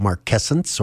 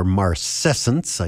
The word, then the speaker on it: marcescence: And you may recall from previous programs where I talked about marquescence or marcescence, I guess it can be pronounced either way.